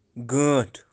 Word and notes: Ganth: Kashmiri

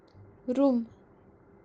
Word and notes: Rum: kashmiri

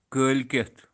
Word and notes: kael-keth: kashmiri

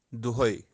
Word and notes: dohai: kashmiri

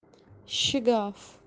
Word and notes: shigaaf: kashmiri

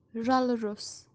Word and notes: raluros: Kashmiri

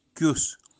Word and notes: Kyus: Kashmiri

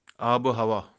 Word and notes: abuhawa: kashmiri

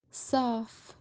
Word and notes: saaf shod: kashmiri

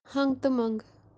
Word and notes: hangutumangu: kashmiri

